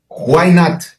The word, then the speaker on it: Why not: Why not? Es una forma coloquial, claro, de decir en inglés, no? Why not, correcto.